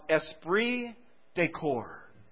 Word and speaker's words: esprit de corps: In this word, it's pronounced esprit de corps. It's a beautiful word. It's a French word, not Spanish.